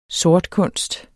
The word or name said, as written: sortkunst